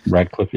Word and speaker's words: Radcliffe: Or Radcliffe? Is that how you pronounce that kid's name? That's Radcliffe, yeah.